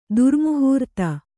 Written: ಧಾರೆಮುಹೂರ್ತ

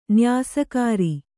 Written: ನ್ಯಾಸಕಾರ

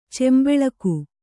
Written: ಚೆಂಬೆಳಗು

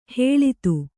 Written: ಹಳತು

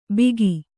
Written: ಬಿಗಿ

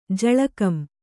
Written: ಜಳಕಂ

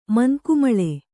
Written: ಮಂಕುಮಳೆ

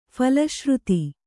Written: ಫಲಶ್ರುತಿ

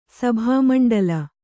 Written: ಸಭಾಮಂಡಳ

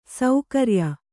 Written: ಸಕ್ರಿಯ

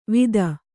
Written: ವಿತ್ತ

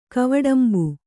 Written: ಕವಡಂಬು

